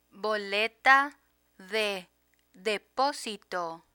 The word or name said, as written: boleta de deposito